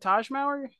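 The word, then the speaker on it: Tahj Mowry: That's how you say it, Taj Mowry? – Yeah. – I thought it was Ty Mowry. – No, Taj Mowry.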